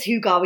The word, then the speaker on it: hygge: And it is hygge. We can now, we know how to say it.